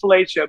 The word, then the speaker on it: Filica: I said, it's like Felicia, but it's spelled F-I-L-I-C-A.